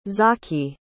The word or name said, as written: Zaki